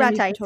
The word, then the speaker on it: ratites: They're also known as ratites. I don't know if I said that correctly. – Ratites, Ratites, yes.